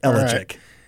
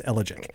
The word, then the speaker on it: ellagic: It's ellagic. Right. Kind of rhymes with allergic, but it's ellagic.